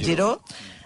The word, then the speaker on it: Giraud: Escrit Giraud, però pronunciat Giraud.